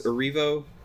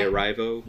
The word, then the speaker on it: Erivo: And when I sat there and I tried to piece it all together, I really did think that Cynthia, I think it's Erivo. I always say, I feel like I get her name wrong because I used to say Arrivo.